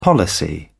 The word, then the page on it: policy: British English